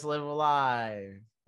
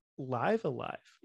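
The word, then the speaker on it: Live A Live: But see, uh, live, live, live alive, live alive, live alive. Again, I'm pretty sure cool. it's live alive. Oh, yeah, I think you're right.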